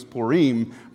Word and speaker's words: Purim: P-U-R-I-M. I know that doesn't pronounce in the English as Purim, but I promise you that is how we are going to walk forward saying it, Purim.